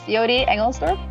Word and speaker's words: Jody Engelsdorp: Yes, Jody Engelsdorp.